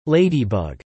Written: ladybug

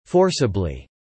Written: forcibly

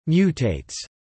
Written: mutates

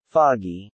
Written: foggy

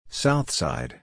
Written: Southside

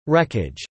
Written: wreckage

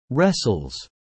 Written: wrestles

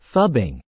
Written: phubbing

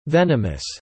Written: venomous

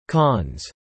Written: cons

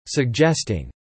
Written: suggesting

